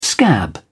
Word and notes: scab: British English

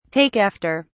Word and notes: take after: British English

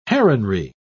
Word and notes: heronry: British English

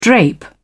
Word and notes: drape: British English

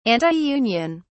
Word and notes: antiunion: British English

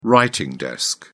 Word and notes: writing desk: British English

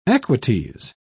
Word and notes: equites: British English

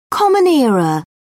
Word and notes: common era: British English

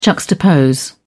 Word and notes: juxtapose: British English

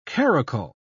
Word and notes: karakul: British English

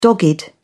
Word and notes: dogged: British English

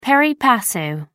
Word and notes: pari passu: American English

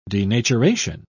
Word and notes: denaturation: British English

